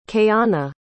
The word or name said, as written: kayanna